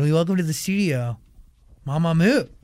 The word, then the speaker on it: Mamamoo: Mamamoo라는 이름을 할 때 약간 긴장을 하신대요. 그 발음이 맞는지 마마무가 맞는지 편하게 해달라고. – Just say comfortably, Mamamoo is fine.